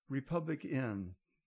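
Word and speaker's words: RepublicEN: It's called Republic N, but it's spelled Republic. E-N.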